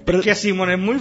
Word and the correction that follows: Simmons: Simons. Terminan de S, no es Simon, Simon.